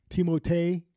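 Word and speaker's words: Timothée: Bro, I think, like, Timotei, because that's how you say his name. Do you know that? – No, it's not Timothy. It's Timotei.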